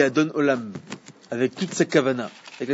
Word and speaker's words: Adon Olam: Adon Olam.